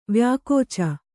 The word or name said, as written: ವ್ಯಾಕೋಚ